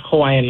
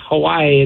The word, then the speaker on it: Hawaii: We're also like, technically we're also pronouncing Hawaii wrong. Like Hawaii, it's Hawaii, you know, because in Hawaiian language, the W's are actually, they sound like V's.